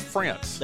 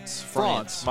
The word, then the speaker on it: Frantz: Chris France. – There you go. – But it's spelled F-R-A-N-T-Z. – France.